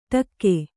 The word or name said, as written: ಡಾಕು